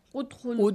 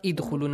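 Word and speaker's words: Udkhulu (ادخلوا): Khu has zamma, yes? So how should we say it? – Udkhulu. – Udkhulu, not idkhulu, not adkhulu.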